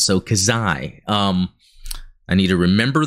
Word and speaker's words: Xi: This is normally pronounced Kazai. Kazai.